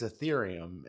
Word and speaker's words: Ethereum: I think it's, like I said, I think it's because it's Ethereum and it's a long E, like it's a hard E or whatever.